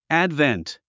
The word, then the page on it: advent: ǽdvent; アァドベント